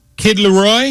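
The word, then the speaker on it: Kid Laroi: Up next is Kid Leroy.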